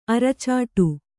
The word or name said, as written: ಅರಚಾಡು